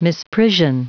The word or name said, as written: misprision